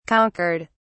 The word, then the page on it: conquered: British English